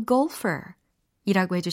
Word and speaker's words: golfer: You are a natural golfer. 이라고 해주시면 됩니다. 골퍼가 아니라 golfer이라고 해주셔야 되겠죠.